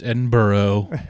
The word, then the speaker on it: Edinburgh: I know it's Edinburgh, but it's spelled Berg, and it annoys me.